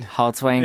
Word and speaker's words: Haltiwanger: Haltiwanger.